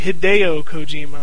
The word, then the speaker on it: Hideo Kojima: If you're not exactly sure what this is basically the third Boktai game from Metal Gear Solid creator Hideo Kojima. I'm sorry, Hideo Kojima. I got to pronounce that right. – Yes.